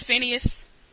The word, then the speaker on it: Phineas: So, is it pronounced Phineas? – Yes.